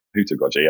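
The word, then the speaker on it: heutagogy: So leaving us on that bombshell of hootagogy, I think. Am I saying that right? Hootagogy? – Hootagogy, absolutely spot on.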